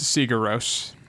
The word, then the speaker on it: Sigur Rós: Sigur Rós. You know, Jon Thor Bergeson he was the guy who did all the music. – Sigur Rós. – It's Sigur Rós. This how it's pronounced. I'm pretty sure.